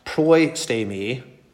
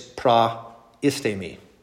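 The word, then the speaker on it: praistemi (προΐστημι): So this is not proistemi, it's praistemi. So there's actually four syllables there. Pra-i-ste-mi. See that?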